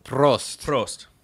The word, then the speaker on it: Prost: Prost. – I don't think you're supposed to roll your R's. In Germany, but that's okay.